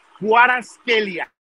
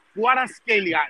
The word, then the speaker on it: Kvaratskhelia: Hablamos con unos georgianos y la pronunciación correcta es Kvaratskhelia. Kvaratskhelia. La V suena como U.